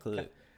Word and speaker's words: Khalid: Khalid? – Yeah, Khalid.